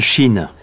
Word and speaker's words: Schin: Oui, N-E-N-A-D. – Deux. – Je m'appelle Shin. – Pardon ? Épelé, s'il vous plaît. – S-C-H-I-N.